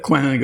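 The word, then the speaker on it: Coing: Ja, aber er ist hugenottischer Abstammung. Also in Frankreich wäre er Coin gewesen.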